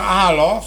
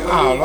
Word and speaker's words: Halo: Il brano Halo, si legge Halo, Halo. Va bene, Halo.